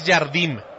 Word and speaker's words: Jardim: Este sí es Jardim, con M. Se escribe como Jardín, pero sin la N, con una M.